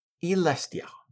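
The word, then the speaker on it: Y'lestja: It's spelled Y apostrophe L-E-S-T-J-A. So I'm going to go with, and I hope I'm going to consistently go with, Elestia.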